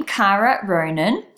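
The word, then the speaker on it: Kara Ronin: So if you want to check out my YouTube channel, head over to YouTube, type in my name, Kara Ronan. I'll spell it for you. It's K-A-R-A-R-O-N-I-N.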